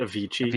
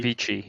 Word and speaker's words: Avicii: It's very bad uh, a Visci Invector Encore Edition, Meganoid, Adventures of Pip. – I believe that was pronounced Avicii. Avicii, yeah.